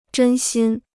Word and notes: 真心: zhēn xīn

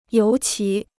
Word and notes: 尤其: yóu qí

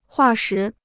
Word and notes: 化石: huà shí

